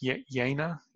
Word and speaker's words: Jena: You know, I recently also read about, there was the town of, I guess it's pronounced, it's spelled J-E-N-A. It's a town in Germany. I guess that would be pronounced Jena. The J in Germany is pronounced the Y, isn't it?